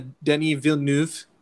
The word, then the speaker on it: Denis Villeneuve: We just did a Denis Villeneuve. I really like to just really enunciate his name when I get the chance. I'm confident that's how you say it.